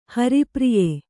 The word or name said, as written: ಹರಿಪ್ರಿಯೆ